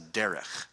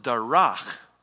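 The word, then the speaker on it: Derek (דרך): That's a Derek. But if you take that exact same word and pronounce it Darach, it means to trample. It becomes a verb. To trample or to tread down.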